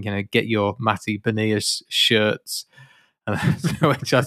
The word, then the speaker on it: Beniers: The only thing that was great about the 5-3 away game was that was the first game for and I've been calling him Matty Bernier, but I've realized that I'm pronouncing that entirely incorrectly, and it's Beniers.